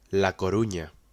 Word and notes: A Coruña: Spanish

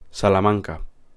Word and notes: Salamanca: Spanish

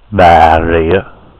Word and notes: Bà Rịa: ɓaː˧˩ ɹiə˧˨˧ˀ; ベトナム語

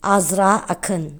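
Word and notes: Azra Akın: Turkish; female

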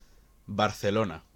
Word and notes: Barcelona: Spanish

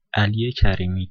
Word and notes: Ali Karimi: [ɑli kæriˈmi]

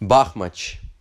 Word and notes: Бахмач: ˈbɑxmɐt͡ʃ; Ба́хмач

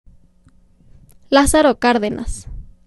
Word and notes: Lázaro Cárdenas: [ˈlasaɾo ˈkaɾðenas]; Spanish

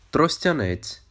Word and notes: Тростянець: trɔsʲtʲɐˈnɛt͡sʲ

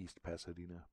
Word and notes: East Pasadena: U.S. English

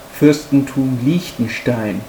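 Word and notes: Fürstentum Liechtenstein: German

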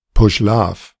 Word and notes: Puschlav: German

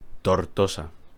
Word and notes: Tortosa: Spanish

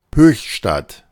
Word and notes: Höchstadt: German; [ˈhøːçˌʃtat]

